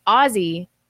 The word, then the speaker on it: Aussie: It's pronounced Aussie, like the Osborne.